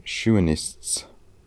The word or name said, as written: Shuenists